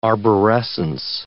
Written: arborescence

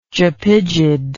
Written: japygid

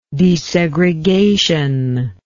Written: desegregationist

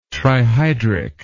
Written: trihydric

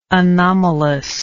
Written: anomalousness

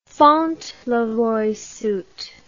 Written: fauntleroy suit

